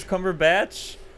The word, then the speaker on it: Cumberbatch: I thought his name was Cumberpatch. – That's what I thought, too. – Like a cucumber patch. – Benedict Cucumber Patch. – Wait, have you ever seen the videos of him pronouncing penguin? – No. – So funny. – How does he say it? – He says it as Ponguin? – Ponguin. Oh, what? His name's Cumberbatch?